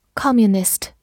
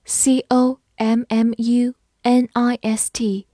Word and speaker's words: communist: Communist, C O M M U N I S T, communist, C O 发 c，M M U m N I S T, nist, communist, communist. 美式发音字母 O 的口型比较大，communist 也是可以的。